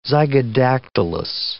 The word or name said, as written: zygodactylous